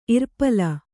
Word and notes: ಇರ್ಪಲ: irpala

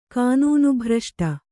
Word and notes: ಕಾನೂನುಭ್ರಷ್ಟೆ: kānūnubhraṣṭe

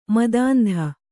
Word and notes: ಮತಾಂಧ: matāndha

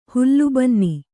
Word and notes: ಹುಲ್ಲುಬನ್ನಿ: hullu banni

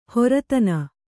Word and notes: ಹೆರತನ: heratana

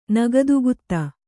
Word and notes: ನಗದುಗುತ್ತೆ: Kannada

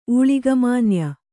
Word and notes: ಊಳಿಗಮಾನ್ಯ: ūḷigamānya

